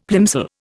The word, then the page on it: plimsoll: Indian